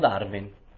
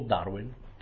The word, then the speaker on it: Darwin: Allora prendiamo Darwin, o Darwin, come lo vogliamo pronunciare, facciamo una cosa all'inglese.